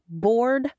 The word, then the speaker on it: Bordeaux: We don't pronounce our name like our French sister city either. Some bright ancestor of mine who settled this valley in the 1800s pronounced our town name Bordeaux. Yep, like some bull standing out in a field wondering what to do with his day.